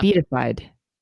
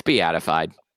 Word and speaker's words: beatified: Oh, is that how you say that word? I always said beatified. – It's beatified. – Beatified? – Mm-hmm. – Damn, I've been saying it wrong.